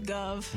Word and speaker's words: Guv: G-U-V. – G-U-V, Gov. – Gov. – Yeah. – That's how you're supposed to pronounce it.